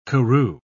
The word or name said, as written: karoo